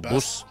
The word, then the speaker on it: Buss: A real estate guy called Jerry Buss. – Yes. – I don't know if you pronounce it Buss or Buss. – Buss. – Jerry Buss, B-U-S-S.